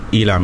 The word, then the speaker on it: ELAM: Is that how you pronounce it? – Elam, yeah.